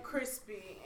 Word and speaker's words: Chrispy: And I am Crispy, and that's spelled C-H-R-I-S-P-Y, also known as, you ain't been accustomed to going through customs.